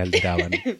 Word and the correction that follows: Aldebaran: Aldebaran, no alteraban. siempre lo digo mal.